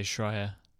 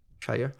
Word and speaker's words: Schreier: I would say Schreier. Schreier.